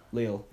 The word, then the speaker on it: Lille: Lille.